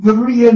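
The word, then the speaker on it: Berea (Βέροια): And so he went from there to what is now pronounced and called in Greece, Berea. B's are pronounced as V's.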